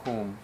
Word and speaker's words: Hum: And in Rinpoche's book, the last syllable he writes out in English, Hum, H-U-N. Usually it's H-U-M. It has a little delicate pronunciations that I've heard. But we're going to do like a Hum. Sometimes it's hung or whom. So Om Ah Hum. Like whom is going with you.